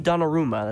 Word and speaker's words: Donnarumma: It's Italian, but it does not sound Italian. It sounds like, you know the villain in the first Doctor Strange movie, Dormammu? And he keeps having to go, Dormammu, I've come to bargain. That's what Donnarumma, that's how you say it.